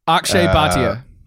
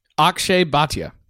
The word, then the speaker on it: Akshay Bhatia: Let's try to pronounce it. – Akshay Bhatia. – Say that again. – Akshay Bhatia. – There you go.